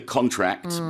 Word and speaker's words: contract: It's different to the noun contract, or when we make a contract, but we pronounce that differently, and that's a different meaning altogether. When we say contract, it means to tighten, to make smaller.